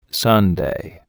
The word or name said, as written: sundae